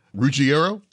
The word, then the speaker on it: Ruggiero: For Eben, he'll join us again, too, at the end of the week when we speak with Angela Ruggiero.